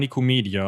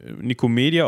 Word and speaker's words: Nikomedia: Ich würde sagen Nikomedia, aber ich bin da auch vorgeprägt durch den Lateinunterricht. – Ach so, ja, aber gut, wenn, wenn das im Lateinunterricht äh, Nikomedia ausgesprochen wurde, dann ähm, bleiben wir dabei.